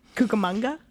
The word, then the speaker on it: Cucamonga: Police believed his body was at the bottom of the Cucamonga Vineyard Lime Pit. Does that ring a bell to you? – No, but I'm just proud of you for pronouncing that correctly. – Cucamonga? – Yeah.